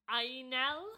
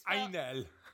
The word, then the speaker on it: angel: It's spelt the same, but it said like, Ainel. – And it sounded really nice, did it? – Yeah, because I'm not good at pronunciation. But Ainel.